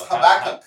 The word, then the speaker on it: Habakkuk: How do you say that? I don't even know. – It's Habakkuk. – Habakkuk.